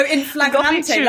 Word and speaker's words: in flagrante: How do I say that phrase that people use that I quite like? Infragrande. – What? Oh, in flagrante, like sexual, in coitus.